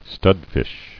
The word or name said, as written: Studfish